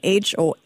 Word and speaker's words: Homeez: Homeez, everybody who is Googling right now, trying to find out more about the company.